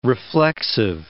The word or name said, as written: reflexive